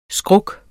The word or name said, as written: skruk